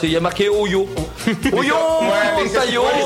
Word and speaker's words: Ohio: Et ça s'écrit comme ça, mais ça se dit comme ça. – O-Y-O. – Voilà. Non, O-I. Non, O-H-I-O.